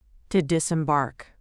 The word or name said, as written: to disembark